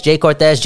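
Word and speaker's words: Jhay Cortez: The remix by Jay Cortez. Am I saying that right? Yes, Jay Cortez, Jay Balvin, and Bad Bunny.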